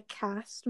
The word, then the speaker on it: caste: So I found out what like the cast means, like the CASTE. I found out what that means. It's pronounced cast.